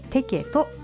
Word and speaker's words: TEKET: チケットは電子チケットサービステケト、これはアルファベットで TEKET と書いてテケトと言います。